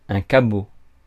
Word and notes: cabot: France; [ka.bo]

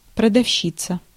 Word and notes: marchand: France; IPA: [maʁ.ʃɑ̃]

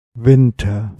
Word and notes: talvi: [ˈt̪ɑlʋi]; /ˈtɑl.ʋi/

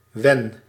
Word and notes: when: /ˈwɛn/; /ʍɛn/

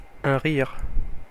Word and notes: laugh: /læf/

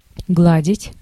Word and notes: гладить: /ˈɡladʲɪtʲ/; gladit

